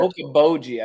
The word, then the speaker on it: Okeechobee: Is it Lake Okeechobee? Did I pronounce that right? – Okeechobee, I believe.